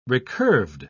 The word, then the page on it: recurved: British English